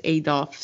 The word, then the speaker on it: Adolphe: We have Adolphe, or is this just Adolph, silent E? – I thought it was Adolph, silent E.